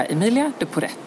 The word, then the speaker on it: Emilia de Poret: Jag tycker faktiskt att man ska säga Emilia de Poret. – Ja, precis. Om man uttalar T.